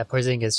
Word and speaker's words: Porzingis: Kristaps Porzingis. – Yeah, Porzingis.